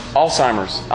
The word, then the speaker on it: Alzheimer's: Am I saying it wrong? Alzheimer's? Alzheimer's? Huh? How do I say it? Alzheimer's. I was pretty close. Thank you.